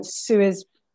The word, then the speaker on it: Suez: You know, I remember you were mentioning Suez blockade. – Oh, that's right. – Apologies for my pronunciation. – No, you said it right there, because I kept on wanting to say sewage blockage, You said Swiss blockage.